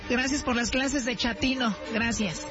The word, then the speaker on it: chatino: Chapino. – ¿Chapino? – No, chatino. – Es como chato, ¿verdad? Chatino. – Sí, afinadero como chato, chatino.